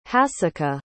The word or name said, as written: hasika